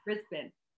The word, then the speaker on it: Brisbane: I pronounced it correctly. Brisbane.